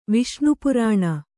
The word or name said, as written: ವಿಷ್ಣುಪುರಾಣ